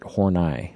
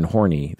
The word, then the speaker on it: Horney: Her last name is spelled Horney, but in German you pronounce it Horney. And so I made an episode once referring to her as Karen Horney the entire time, which was funny to a lot of listeners.